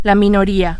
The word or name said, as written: minoria